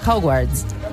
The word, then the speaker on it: Hogwarts: Digo yo, ¿cómo se dice? Hogwarts.